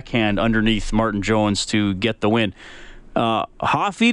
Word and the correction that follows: Hafiz: did I say your name right? Yeah, it's Hafiz. It's Hafiz. oh, Hafiz? Yeah.